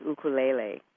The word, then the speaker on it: ukulele: And, and I used to say ukulele my whole life until I went to Maui for 8 months. And, you know, they pronounce it ukulele. So I had to change that.